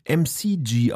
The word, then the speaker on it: MKG: Und wie er auch immer auf diesen Künstlernamen dann gekommen ist, frage ich mich, weil er nennt sich MKG, weist aber darauf hin, dass dieses MKG MCG ausgesprochen wird. – Ah, also nicht wie das Museum für Kunst und Gewerbe.